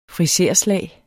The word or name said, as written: friserslag